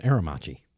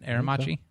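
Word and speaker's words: Aermacchi: It's an Aramachi. – Is that how you say it? Aramachi? – Mm-hmm.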